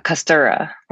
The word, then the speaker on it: Kostura: Uh, Kostura.